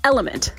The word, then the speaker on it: LMNT: It's LMNT, pronounced Element.